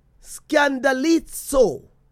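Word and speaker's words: scandalizo (σκανδαλίζω): Now, the Greek word that is translated here, yes, falling away, is pronounced scandalizo.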